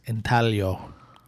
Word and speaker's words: intaglio: Well, the 1911 stamp was uh, printed by the Bureau of Engraving and Printing, uh, was engraved via the intaglio process. Did I say that right? I think it's intaglio. Oh, Sorry. I think the G is silent. Just like in tube sock.